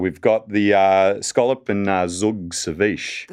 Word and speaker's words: zhoug: Zug is a coriander relish from Yemen. – Oh. – Yeah. – How do you spell that? – Z-H-O-U-G, or H, zug. I should say, shouldn't I? – It's a what kind of relish?